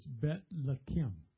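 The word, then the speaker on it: Bet Lechem (בית לחם): The way it's pronounced in Hebrew is Bet Lechem.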